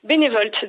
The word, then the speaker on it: Bénévolt: Et comment c'est venu cette idée de bénévolt? C'est ça? Ça s'écrit B-E-N-E-V-O-L-T? C'est ça, bénévolt? – Oui, c'est ça. Bénévolte, donc l'énergie.